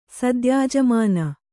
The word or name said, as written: ಸದ್ಯಾಜಮಾನ